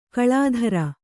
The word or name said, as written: ಕಳಾಧರ